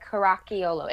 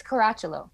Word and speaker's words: Caracciolo: You guys got the ch sound, because a lot of people say, like, Caracciolo. It's Caracciolo.